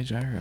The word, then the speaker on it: gyro: There's two ways to say this word. The Greek people will say gyro. Everyone else says gyro. It's actually gyro, though. That's the proper pronunciation.